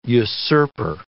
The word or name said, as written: usurper